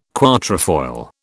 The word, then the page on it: quatrefoil: British English